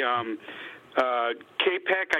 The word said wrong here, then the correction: Kopech: Kopech uh, is how you pronounce the White Sox guy.